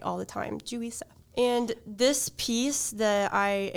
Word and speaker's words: Juri Seo: Uh, it's spelled J U R I and then S E O. And in her bio on her website and everything, she does have at the bottom the uh, North American pronunciation help, which That's says J E W R I S U H.